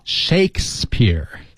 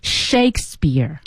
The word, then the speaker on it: Shakespeare: 莎士比亚、这个著名的人物名字啊、同学们一定要知道怎么说。 – Shakespeare. – Shakespeare. – 对 just two syllables.